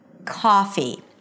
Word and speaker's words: coffee: Now, the word coffee starts with a K sound and then the O that is usually associated with this neutral O sound by non-native speakers is pronounced as ah. Ah, it's actually the ah as in father, okay?